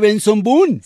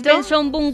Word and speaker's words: Benson Boone: Pero es Benson Boone Gómez. – Les voy a decir exactamente. – Benson Boone Fernández. – Se escribe Benson Boone. Boom como bom bom boom.